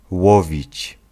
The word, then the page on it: fish: /ˈfɪʃ/; [fɪʃ]